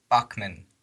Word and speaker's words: Bachman: Bachman. – Like Richard Bachman.